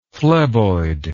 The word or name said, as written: phleboid